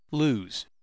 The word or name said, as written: lose